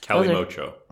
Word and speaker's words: calimocho: It's pronounced I got you addicted, huh? – I can handle those. – Calimocho. – Calimocho. – That's how you pronounce it.